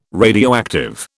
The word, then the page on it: radioactive: British English